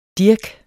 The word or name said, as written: dirk